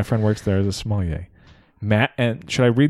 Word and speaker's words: sommelier: How do you pronounce that word? Sommelier? Is that how it is? – Sommelier.